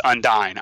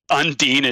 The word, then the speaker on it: Undyne: And I know it's Undyne. I know that that's how her name is pronounced. – Okay. – But Undine is just stuck in my head.